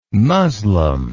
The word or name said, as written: muslem